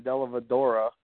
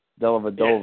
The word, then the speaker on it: Dellavedova: I called Mike because Mike kept saying Dellavedova. So I told Mike it's aggravating to everybody and to please say his name properly. Dellavedova.